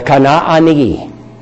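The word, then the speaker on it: Kana'ani (כנעני): The Kana'ani. We call them Canaanites, but they're really, it always should be said, Kana'an. That's how Canaan is written. That's what the two A's, each one of them is pronounced.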